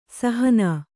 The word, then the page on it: ಸಹನಾ: sahanā